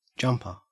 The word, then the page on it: jumper: Australian English